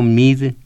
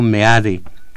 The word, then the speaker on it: Meade: Yo no digo mid, yo digo meade. Y le digo a usted, digamos Oscar Lewis. ¿Por qué hablamos español y no inglés? Se escribe y se pronuncia meade.